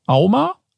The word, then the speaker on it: AUMA: Und die Auflösung ist, im Jahr 2022 wurden laut Auma AUMA, mhm.